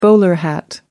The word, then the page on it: bowler hat: American English